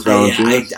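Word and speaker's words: Valančiūnas: I'm so sorry, I can't pronounce this guy's name. Jonas, J-O-N-A-S. – Oh, Jonas Valanciunas.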